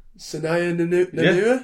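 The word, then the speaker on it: Sennia Nanua: I bet I won't be able to pronounce this. Christ. Yeah, the child actor who plays Melanie, Senaya Nanua. Yeah. Senaya Nanua? – Yeah. – Yeah. – Cool. – Senaya. Brilliant.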